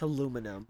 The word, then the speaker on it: aluminum: An aluminium one, a wooden one. – It's not how you say that word. – It's totally how you say it. – It's aluminum.